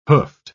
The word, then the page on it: hoofed: British English